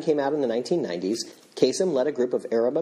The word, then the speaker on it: Kasem: Now, if I'm pronouncing this right, he was born Kamal Kasem, and he changed his name at some point to Casey Kasem. He kind of put the emphasis in a different place.